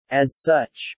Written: As such

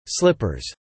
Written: slippers

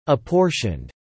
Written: apportioned

